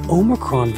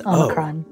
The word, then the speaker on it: Omicron: I mean, I've been saying it's Omicron. Oh. It's like somewhere in between. It's neither ah nor.